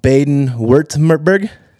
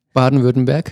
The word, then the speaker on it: Baden-Württemberg: He received his BA from the State University of, I'm going to pronounce this wrong, Baden-Württemberg. – Baden-Württemberg. – There you go. I told you. I'm a Texan. What can you say? – It was so off.